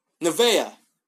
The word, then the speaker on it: Nevaeh: Nevai? It's heaven backwards. Nevea. Yeah, I think that's it.